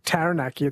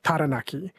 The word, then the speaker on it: Taranaki: Like, I noticed the rule, one that I tried is for Māori pronunciation, is they don't have the A sound like cat. It's instead of Taranaki, it's Taranaki, Taupo.